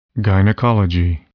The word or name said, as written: gynecology